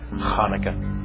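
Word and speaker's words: Chanukah: In a previous devotional, we discussed the origins and history of the Jewish holiday called Hanukkah, sometimes spelled with a C at the beginning and pronounced with a hard guttural H, like Hanukkah.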